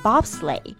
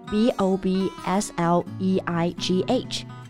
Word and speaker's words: bobsleigh: 那雪车就不叫做 snow car，而是叫做 bobsleigh。 B O B S L E I G H，那结尾的 G H 就是不发音的 bobsleigh。